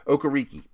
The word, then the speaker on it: Okereke: Reiki, I always say his name wrong, and it's not it's not a hard one to say, but I always say it wrong. Okereke one real note on him length.